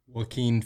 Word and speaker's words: Joaquin: Do you think you could pronounce his name properly right here on the show? – Joaquin Phoenix. – Oh, my goodness. That's the one.